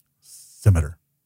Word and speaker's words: scimitar: Scimitar. – Hey, I'm thinking if Podcrawl lady scimitar. And Riker both pronounce it like that, that's the pronunciation.